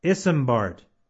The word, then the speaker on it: Isambard: His first name is Isambard, and you spell that I-S-A-M-B-A-R-D, Isambard. And his last name is Brunel, and that is spelled B-R-U-N-E-L, Brunel.